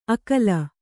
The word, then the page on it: ಅಕಲ: kannada